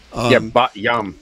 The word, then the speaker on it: Bat Yam: Some have hit in Tel Aviv and Haifa, and also in, I don't know if that's spelled or pronounced exactly the way it sounds, but Bat Yam. – Bat Yam.